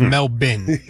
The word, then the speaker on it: Melbourne: The Australian pronunciation, Sal, is Melbourne.